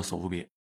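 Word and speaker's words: ソフビ: 然后呢，日本人呢其实给它简化了，就简化成叫索夫比。 – 就日本英语把 Soft Vinyl 发音发成了 Soft B。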